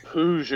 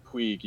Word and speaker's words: Puig: You probably said it pretty close. Like, it's I guess it's from like the France Barcelona kind of like border or something. And apparently you pronounce it like PUiG or something. – I go with the Spanish pronunciation of like PUiG usually.